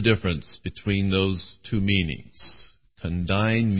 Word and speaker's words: condign: And these two words are condign and congruous. Condign, spelled C-O-N-D-I-G-N, it has a silent G in that word, comes from the Latin word condignus, and it means adequate.